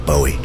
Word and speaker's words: Bowie: Apparently, the correct pronunciation is Bowie, just in case you're one of those people who says it incorrectly.